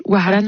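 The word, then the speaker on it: Wahran: Oh, tu sais, t'es vraiment très proche. Waran, Waran, qui veut dire Oran.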